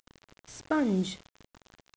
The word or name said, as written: sponge